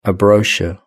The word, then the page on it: a brochure: British English